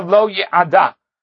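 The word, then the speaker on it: lo ye'ada (לו): It says, imra'a b'nei aduna, asher lo ye'ada. Over there, it's spelled lamed aleph, lo as if no, but it's pronounced lamed vav, lo ye'ada, which means that he took her as a wife.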